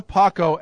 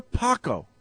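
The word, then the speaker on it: Palko: Wanstead had Joe Flacco as its quarterback and picked Tyler Pacco. Or Paco. I don't know how you pronounce his name. It's P-A-L-K-O. Pacco. Paco. But he had Flacco.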